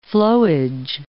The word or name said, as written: flowage